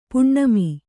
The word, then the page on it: ಪುಣ್ಣಮೆ: kannada